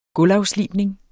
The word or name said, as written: gulvafslibning